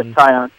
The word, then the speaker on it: Taillon: I think I'm pronouncing that right, Taillon. – Yeah, Taillon.